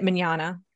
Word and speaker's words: Mignogna: He pronounces it Mignogna.